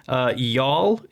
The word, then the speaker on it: Youghal: Youghal is spelled Y-O-U-G-H-A-L.